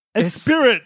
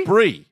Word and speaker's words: Esprit: Yeah, and you would definitely pronounce that Esprit. – Of course you would. – Esprit. – It's French.